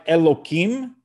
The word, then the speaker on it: Elokim: So it's pronounced like Elokim, but it's written the letters, the vowelization is Elokim, and the letters are the name of God, Yud Kei Vav Kei.